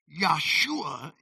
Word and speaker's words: Yahshua: Yahshua is the actual Hebrew pronunciation of it, and the two Hebrew words are Yah and Shua.